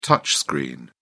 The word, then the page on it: touchscreen: British English